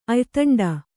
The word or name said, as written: ಅಯ್ತಂಡ